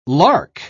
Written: lark

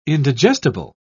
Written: indigestible

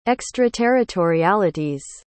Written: extraterritorialities

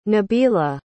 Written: nabila

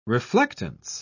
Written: reflectance

